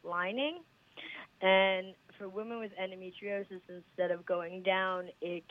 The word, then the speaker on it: endometriosis: But you mentioned to people, and I'll probably pronounce it wrong, that you've had endometriosis. Did I say that right? – Yeah, endometriosis.